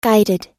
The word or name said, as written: guided